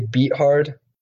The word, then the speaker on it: Beathard: C.J. Beathard. That's the correct pronunciation of his name.